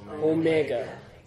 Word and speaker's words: omega: And then omega. Omega. O. Mega, as in mega. Big. Big O. Long O. Omega.